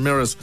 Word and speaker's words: Mraz: Pero es Jason M-R-A-Z, pero se pronuncia Jason Mirrors, el invitado en el especial musical ahí del Trasnoche.